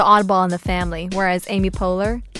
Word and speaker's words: Poehler: I think I respect Tina Fey, but I think Amy Poehler's would be more like... – Amy Poehler? Would you have to get her name right, first of all? – Sorry. – Hello, Mr. and Mrs. Poehler.